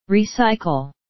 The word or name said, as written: recycle